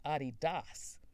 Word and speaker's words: Adidas: And by the way, the correct pronunciation for Adidas is Adidas.